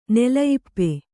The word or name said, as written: ನೆಲಯಿಪ್ಪೆ